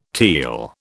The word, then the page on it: teal: British English